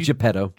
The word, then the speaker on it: Geppetto: Geppetto.